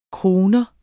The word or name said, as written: crooner